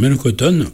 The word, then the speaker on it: Mélocotton: Et j'ai souhaité que nous écoutions Mélocotton, qui a bercé effectivement ma vie de jeune adulte. – Alors Mélocotton ou Mélocoton ? – Mélocotton, avec un accent tonique sur la dernière syllabe.